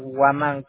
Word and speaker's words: Huaman: En español, Waman con H-U-A-M-A-N. – N de Nicolás al último, ¿no? – Ya, yeah, ajá. – H-U-A-M de María, A-N de Nicolás.